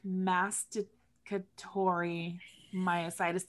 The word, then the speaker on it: masticatory myositis: So, this week we're going to be, so our episode this week is masticatory myositis.